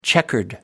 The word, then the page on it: checkered: American English